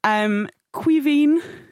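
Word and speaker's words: Caoimhín: Quivín Kelleher is how we're pronouncing that excellent gentleman's name. Mm. Please do write in if you've got issues with it.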